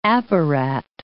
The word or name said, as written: apparat